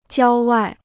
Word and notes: 郊外: jiāo wài

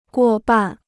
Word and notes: 过半: guò bàn

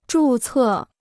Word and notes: 注册: zhù cè

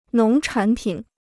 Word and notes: 农产品: nóng chǎn pǐn

